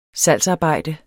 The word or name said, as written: salgsarbejde